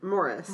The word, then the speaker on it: Maurice: So Maurice versus Morris, Maurice is originally a French name, and they pronounce it Maurice there. And in the States, usually it's Maurice. And then in the UK, from what I've read anyway, it could be Maurice or Morris.